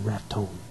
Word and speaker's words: Raton: It runs through Denver, Colorado Springs, Pueblo, Walsenburg, little town of Trinidad, and it goes up over a pass into Raton, New Mexico. That's the way they pronounce it out there, too, Raton.